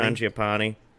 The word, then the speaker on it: Mangiapane: Uh, I don't know how to say it. Mangiapane. Mangiapane. – Mangiapane. Yeah. Uh, Lafreniere, I did pick him up.